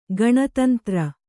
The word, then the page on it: ಗಣತಂತ್ರ: gaṇa tantra